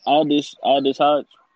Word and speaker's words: Aldis Hodge: Oh, you talking about Aldis Hodge? – Yeah.